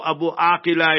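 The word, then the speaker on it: Abu Akleh: So Abu Akila is the proper pronunciation.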